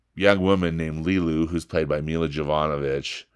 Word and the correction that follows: Jovovich: uh, or Jovovich, excuse me, I'm saying her name wrong, um, who literally drops out of the sky and into his life.